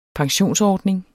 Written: pensionsordning

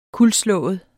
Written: kuldslået

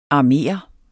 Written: armere

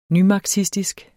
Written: nymarxistisk

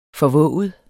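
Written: FORVÅGEDE

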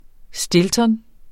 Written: stilton®

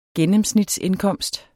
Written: gennemsnitsindkomst

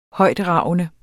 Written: højtragende

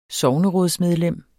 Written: sognerådsmedlem

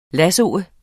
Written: lassoe

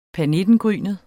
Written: pernittengrynet

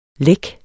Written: lækker

